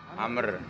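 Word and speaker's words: Amr (عمرو): Tepat kalau Ain Memrok Wawu, bacanya apa? – Amr. – Amr.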